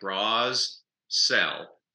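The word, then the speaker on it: Brissell: It's spelled like Bras Cell.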